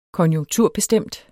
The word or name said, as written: konjunkturbestemt